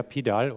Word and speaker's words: PDAL: Ein Pendant dazu für Punktwolken, Point Cloud, Point Data Abstraction Layer, PDAL oder auch PUDL in US-Englisch ausgesprochen, ist eine Software, die wirklich hochinteressant ist, um sogenannte Pipelines aufzusetzen.